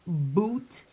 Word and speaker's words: Bhoot: It's spelled B H O O T, but it's pronounced B O O T. – Okay. Gotcha. Silent H.